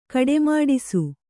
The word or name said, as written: ಕಡೆಮಾಡಿಸು